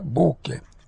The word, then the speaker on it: bucae: tenendo conto che la parola latina bucae si pronuncia buche.